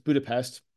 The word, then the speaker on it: Budapest: They were sort of talking about the Puskás Arena in Bucharest. Sorry, not Bucharest, Budapest. I'm looking at it right now. I still said it wrong.